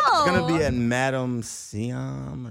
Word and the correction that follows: Madame Tussauds: Madame Tussauds, the wax figure.